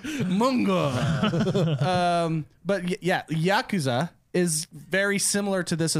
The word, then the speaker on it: Yakuza: Like Yakuza, which is funny. Here's a pronunciation thing. I think it's Yakuza. – Yakuza. – Okay, yeah, we've always been saying Yakuza.